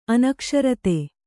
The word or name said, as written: ಅನಕ್ಷರತೆ